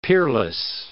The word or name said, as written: peerless